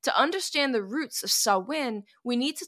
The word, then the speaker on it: Samhain: And by the way, Samhain is spelled S-A-M-H-A-I-N. So it looks phonetically like it should be pronounced Samhain, but it's not. Samhain. Gaelic is different.